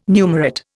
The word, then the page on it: numerate: Indian